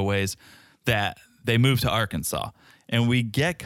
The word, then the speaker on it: Arkansas: It was Liz's idea because Small Ed has a lot of family in Arkansas, that's right.